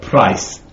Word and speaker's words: Preußen: Well, this is Preußen is pronounced in Bavaria as not Preuss, but Price.